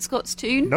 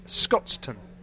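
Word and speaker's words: Scotstoun: Am I accurately calling it Scott's Toon? – Nope. You've got it absolutely wrong. – Correct away, then. – Thanks to listener Jim Boyle and many others, they've pronounced it Scotston.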